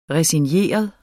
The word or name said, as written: resigneret